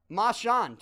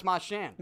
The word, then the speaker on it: Marchand: And then it changed. We said... Marchand, too. – Right. – Now it's Marchand. – But there's a lot of people around here that refuse to say it correctly, so I respect that.